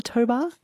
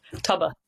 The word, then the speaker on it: tobar: Is that how you pronounce it, a tobar? – Tubber. Yeah.